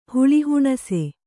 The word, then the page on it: ಹುಳಿಹುಣಸೆ: huḷi huṇase